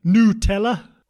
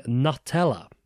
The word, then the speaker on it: Nutella: The debate has finally been put to bed as a team has confirmed the correct pronunciation, saying that the emphasis should actually put on the N and O-U. – Like nougat. Nutella? Like nougat? – Yeah, that...